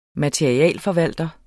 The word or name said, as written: materialeforvalter